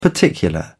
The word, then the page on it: particular: British English